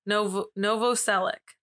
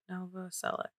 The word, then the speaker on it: Novoselic: And his last name is Novacek. Isn't that what it is? Novacek. Fuck. – Sure. – Of course, I can't remember his name in this. Novacek. Novoselic. I can't pronounce it right ever. Novoselic.